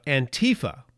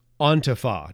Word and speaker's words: Antifa: Their name is actually pronounced Antifa, not Antifa, Antifa. It's a shortened form of the term anti-fascist.